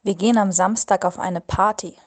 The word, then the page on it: auf eine Party gehen: German